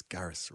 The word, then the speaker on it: Garris: Is it Garris? – It's Garris. Rhymes with Paris, as he says.